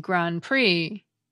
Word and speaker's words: Grand Prix: The same goes for the silent P and silent T in coup d'etat, and the silent D and silent X in Grand Prix.